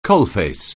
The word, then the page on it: coalface: anglais